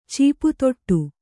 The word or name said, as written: ಚೀಪುತೊಟ್ಟು